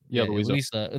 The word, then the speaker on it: Louisa: And then you go to, like, the weirdest feeling field was down at Louisa. Is that how you say it? – Yeah. – Louisa.